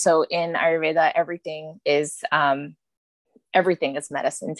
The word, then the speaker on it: Ayurveda: Ayurveda. Ayurveda is an ancient Indian healing art form.